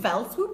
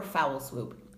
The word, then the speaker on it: fell swoop: Is it fell swoop or foul swoop? – It's definitely fell, not foul. – How do you spell it? – F-E-L-L.